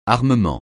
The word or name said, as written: armement